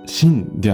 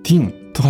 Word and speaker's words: Thin: は、えー、 THIN、痩せてるという意味のティンっていうんですかね、えー、リ理事と表記されていると思うんですけれども、えー、直子さんはそれをご存知の上で、あえて、シンではなく、ティンと発音されているのには、実は訳があります。